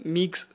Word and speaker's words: Meigs: So the next cause will be the Meigs syndrome, pronounced as M-E-I-G, Meigs syndrome.